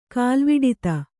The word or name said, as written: ಕಾಲ್ವಿಡಿತ